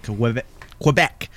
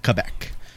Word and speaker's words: Quebec: Quebec. Is that how you pronounce it, or is it Quebec? – Either.